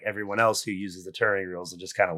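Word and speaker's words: ter'angreal: It's Terangreals. – Thank you. Thank you for that pronunciation.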